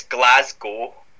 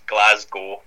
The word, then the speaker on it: Glasgow: No, you say it like most Americans normally say it before they're corrected. So it's Glasgow. So you kind of forget the W at the end, and it's like two words, Glasgow.